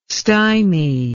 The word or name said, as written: stymy